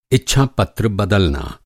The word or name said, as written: इच्छापत्र